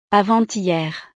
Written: avant-hier

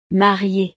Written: marié

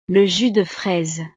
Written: le jus de fraise

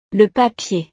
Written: le papier

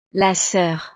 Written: la soeur

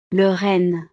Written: le renne

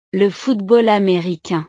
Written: le football americain

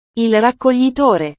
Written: il raccoglitore

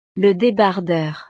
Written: le debardeur

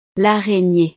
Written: l'araignee

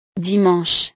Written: dimanche

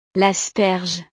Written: l'asperge

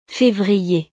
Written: fevrier

